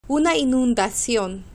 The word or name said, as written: una inundacion